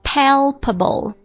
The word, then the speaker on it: palpable: 来读一下这个单词 palpable, P-A-L-P-A-B-L-E。记这个单词的时候啊，如果觉得发音有点拗口啊，我们这样记啊，记三个发音要素，就是 p p b，你看它分成三个音节，有一个辅音 p 音，然后再一个 p 音，再一个 b 音，对不对？